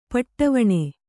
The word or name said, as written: ಪಟ್ಟವಣೆ